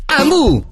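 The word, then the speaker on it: Hamu: Amu si scrive con l'H.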